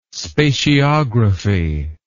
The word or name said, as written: spatiography